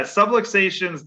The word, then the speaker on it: subluxations: And the subluxation, the subluxations, did I say that right? That's right. Yeah. So yeah, subluxations,